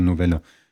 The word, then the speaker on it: Novelle: Und das Kurze heißt aber auch Novelle. Also mit L-E, aber anders ausgesprochen. Also Novelle.